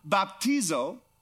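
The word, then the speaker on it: baptizo (βαπτίζω): The word baptism is a Greek word, which means it's pronounced this way, baptizo, right?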